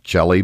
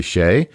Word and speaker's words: Chelly: It's spelled Chelly. It's kind of, you would think it might be pronounced Chelly, but it's not. It's Canyon de Chelly.